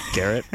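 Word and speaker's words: Gerrit: Maybe your boy, Jarrett Cole. – Garrett? – Yes, Garrett.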